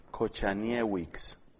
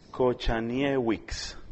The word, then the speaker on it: Kochaniewicz: Como no estamos acostumbrados, claro que ya lo he pronunciado mal, es que estos nombres polacos no es Kaczynski, sino Kocha, Kochaniewicz. O sea que estaba lejos yo. Kochaniewicz.